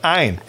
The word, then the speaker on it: Ayn: Look her up on YouTube, Ayn Rand, A-Y-N-R-A-N-D, Ayn Rand. People say it's Ayn Rand, but it's not because she's from Russia and it's pronounced Ayn.